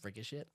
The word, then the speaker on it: Ricochet: It's pronounced Ricochet.